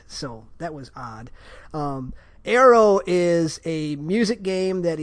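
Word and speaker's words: Aaero: is Arrow.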